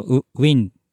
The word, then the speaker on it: Nguyen: ウィンじゃないですか。ラストネームが NGUYEN で。 – そうそう、そういうやつですね。 – なんか、ニューエンって読む人とウィンって読む人がいるらしいんですけど、うんうん。